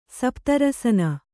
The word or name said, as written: ಸಪ್ತರಸನ